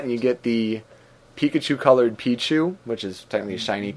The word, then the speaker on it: Pichu: Pichu. – Excuse me, Pichu. That extra syllable screwing me up.